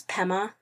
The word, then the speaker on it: Pema: Her name's Pema. Rhymes with Emma.